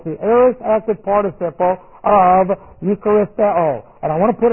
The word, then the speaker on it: eucharisteo: This is E-U-C-H-A-R-I-S, charis is the word grace, T-E and a long O, eucharisteo.